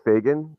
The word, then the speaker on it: Fagan: It's Fagan, F-A-G-A-N. So. – Just like it's spelled. I just wanted to make sure. – Nick Fagan.